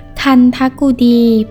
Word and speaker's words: คันธกุฎี: สวัสดีค่ะพบกับรายการคำวัดวันนี้เสนอคำว่าคันทกุดีคำว่าคันทกุดีสะกดด้วยคอควายไม่หันอากาศนอหนูทอทงทันทะกอไก่สระอุกุดอชดาสระอีดีคันทกุดี